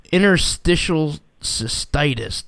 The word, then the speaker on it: interstitial cystitis: You were diagnosed with interstitial cystitis.